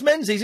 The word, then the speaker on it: Menzies: It's pronounced Menzies. It's not pronounced Ming. It's spelt Ming.